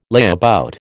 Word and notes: layabout: American English

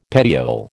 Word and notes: petiole: American English

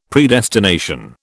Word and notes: Predestination: British English

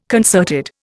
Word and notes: concerted: Indian

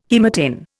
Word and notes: haematin: Indian

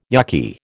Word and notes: yukky: American English

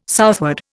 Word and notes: southward: Indian

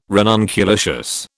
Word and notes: ranunculaceous: British English

